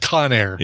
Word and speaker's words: Conair: He invented the Cognier hairdryer. – Incorrect. That would be incorrect. – I guess that's pronounced Conair.